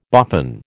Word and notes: boffin: American English

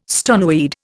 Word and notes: stoneweed: Indian

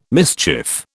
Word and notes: mischief: British English

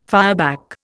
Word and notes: fireback: Indian

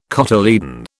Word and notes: cotyledon: British English

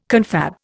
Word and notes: confab: Indian

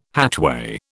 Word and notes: hatchway: British English

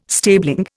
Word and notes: stabling: Indian

